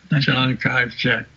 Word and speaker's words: Chiang Kai-shek: Chiang Kai-shek.